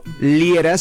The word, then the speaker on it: Liera: Y a tu servidor lo encuentras por mi nombre, Fernando Liera, se escribe L-I-E-R-A.